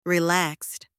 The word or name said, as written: relaxed